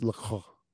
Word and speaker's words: LaCroix: I think it's pronounced LaCroix. – LaCroix. – You got to put that "croix" in there.